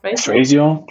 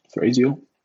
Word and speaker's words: Thrasio: Ich glaube, hat in den USA gestartet mit Thrasio, ich weiß gar nicht, wie spricht man sie denn aus? – Thrasio, glaube ich. Thrasio, ja.